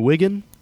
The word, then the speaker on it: Wigan: And the last time they ever played it was in your country of England, in Wigan, I believe it's pronounced.